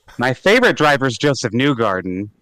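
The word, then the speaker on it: Josef Newgarden: pronounced Joseph Newgarden, Indianapolis 500 champion. That's how you pronounce his name.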